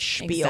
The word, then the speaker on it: Spiel: It's spelled like Spiel but pronounced like Spiel.